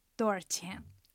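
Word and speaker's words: 多少钱: ドーチェン。 – ドーチェン。 – そう、あのシャオではなくてアーっていう発音に変わってるんですね。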